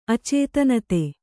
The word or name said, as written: ಅಚೇತನತೆ